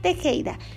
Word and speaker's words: Tejeda: Me vas a encontrar con mi nombre, Melisa Tejeda.